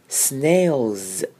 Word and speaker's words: snails: 那 l 后面如果加一个 s 的时候啊，这个 s 就会失去原本 s 的声音，我们会念成 z， like this。 Snails, OK. 好，接下来是看 eaten 这个字。 Eaten 呢，后面的 T-E-N 呢，我会念成嗯，把 T 都变不见喽，所以我们会念成 eaten。